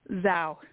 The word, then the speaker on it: Zhao: Zhao.